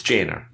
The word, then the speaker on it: Jana Novotna: Now, is it Jana? It's Jana. – Novotna? No, it's Jana. – Jana Novotna. – Yep. – Okay, because pronunciation I'm normally not bad at, but because I play Warcraft, it looked like Jin.